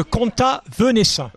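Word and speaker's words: Comtat Venaissin: comment on prononce ? – C'est le Conta Venaissin.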